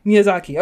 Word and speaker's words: Miyazaki: amazing movie by Mr. Miyazaki. Oh my gosh, I always say his last name wrong. – Miyazaki? – Miyazaki. Okay, great.